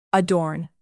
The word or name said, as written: adorn